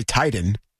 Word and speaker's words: Titan: We don't say Titan. Titan. – Titan. – How do you titan. – Say titan? Titan. – A light T.